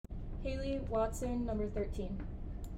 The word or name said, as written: Hayley Watson